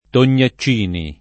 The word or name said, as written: Tognaccini